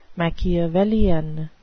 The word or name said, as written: Machiavellian